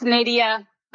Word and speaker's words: Nadia: It's Nadia, but people say it crazy all the time. I think it's because of NyQuil, because it's like Lydia, but with an N, but everybody always tries to say something different.